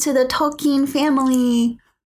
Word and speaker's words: Tolkien: So before you start to lose me, can you address what the correct way to pronounce Tolkien is? Is it Tolkien or Tolkien? – Great question from our listeners. So I think this is probably because of my very bad American accent, but I've been pronouncing his name wrong. Oh no. – Oh boy. – The correct way is Tolkien.